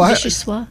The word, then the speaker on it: vichyssoise: And then Leanne said vichyssoise, which I think I'm pronouncing it correctly, but I don't know what it is. Is that some kind of like a soup or something? – Oh, vichyssoise? – You are saying it right, but I don't know what it is.